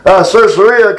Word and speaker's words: Caesarea: Caesarea,